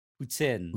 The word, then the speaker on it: poutine: So like, yeah, so you say poutine, but I said that growing up and I got bullied. So I have to say it. – Putin? – How people here say it. Poutine.